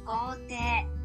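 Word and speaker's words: kote (校庭): kote kote kote kote kalau ditulisnya itu kan kotei tapi dalam pelafalan bahasa Jepang, karena e-nya panjang, iknya nya tidak di, terlalu ditonjolkan. Jadi penyebutan kata ini yaitu kote.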